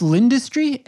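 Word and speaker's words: L'Industrie: Have you been to, that's pronounced Lind Industry, L-I-N? – Yeah, L apostrophe industry.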